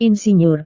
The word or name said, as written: insinyur